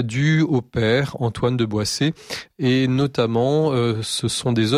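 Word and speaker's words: Boësset: Je pense qu'on devait prononcer Boissé d'ailleurs, même si ça s'écrit Boécé.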